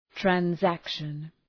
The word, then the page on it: transaction: træn’sækʃən